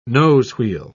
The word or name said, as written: nosewheel